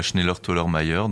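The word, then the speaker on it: Schnellertollermeier: Voilà donc Gina Ete qui démarrera cette soirée du vendredi 18 et qui sera suivie de Schneller Tollermeier. Je l'ai bien prononcé, mon accent germanique est complètement pourri. Mais... – non, non, très bien, ouais, c'est ça, Schneller Tollermeier.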